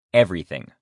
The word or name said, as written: everything